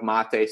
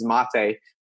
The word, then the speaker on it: Mate: Eventually, I have a lot of phone calls and a lot of online meetings, so I would say about 99 times, they usually say, "How you doing, mate?" instead of mate. So basically, in order to prevent that, because I like my name to be pronounced the right way, I usually say, "Hey, this is mate," before they would say, "Hey, mate."